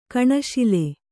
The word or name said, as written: ಕಣುಶೂಲೆ